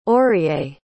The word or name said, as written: orie